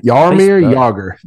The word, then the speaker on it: Jaromir Jagr: Jaromir Jagr.